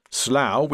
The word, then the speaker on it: Slough: Slough, which rhymes with cow, is about 30 kilometres from central London.